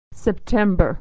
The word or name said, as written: September